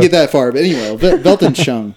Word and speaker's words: Weltanschauung: He says that the worldview came into the English language through the German Weltanschauung. I think that's how you say it. – Yeah, that's right.